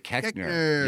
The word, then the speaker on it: Koechner: Tony Colletti and Adam Scott and David Kirchner. David Koechner. You're the only one I ever know who pronounces that correctly.